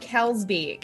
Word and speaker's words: Kalsbeek: Hey, it is Deb Kalsbeek. – Kalsbeek, okay. Just like it's written out, K-A-L-S-B-E-E-K, okay.